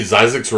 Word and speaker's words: Zyzzyx: Have y'all heard of the movie Zizek's Road? – Zizek? – Yeah, it's spelled Z-Y-Z-Z-Y-X, but it rhymes with Isaacs.